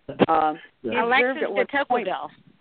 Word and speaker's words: Alexis de Tocqueville: Well, I think the French writer, Alex de Tocqueville, I can't pronounce his last name, Tocqueville. – Alexis de Tocqueville.